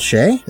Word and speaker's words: Che: Uh, uh, Colin Jost and uh, I hope I'm pronouncing this right. Michael Che. Is, am I saying that right, yeah, dude? – That okay. sounds right.